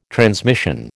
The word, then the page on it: transmission: American English